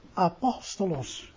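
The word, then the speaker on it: apostolos (ἀπόστολος): En als je dus meer dan twee lettergrepen hebt, dan legt hij de klemtoon op de derde lettergreep van achter. Dus het woord profetos of apostolos, ja?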